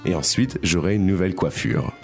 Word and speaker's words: coiffure: So, the pronunciation, what's the difference between that and a hairstylist? – Donc, on a coiffeur. – And that's a hairstylist. – Et puis, coiffure. – Coiffure. And that's a haircut.